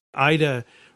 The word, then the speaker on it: Aida: There were examples of, there's a guy from the Ida refugee camp, which is located in Bethlehem. – Ida, how's that spelled? – A-I-D-A. – Okay. – Like the opera, only not pronounced that way.